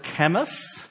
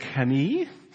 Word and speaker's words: Camus: His name is, because we're doing Australian this morning, thanks to Kez, we would pronounce his name Albert Camus, but if you're French, particularly French Algerian, his name would be Albert Camus.